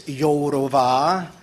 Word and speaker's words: Jourova: I would like you to avoid some embarrassment by pointing out that her name is to be pronounced Mrs. Jourova.